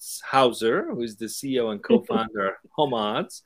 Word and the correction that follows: Homads: Did I say that right? – Homads.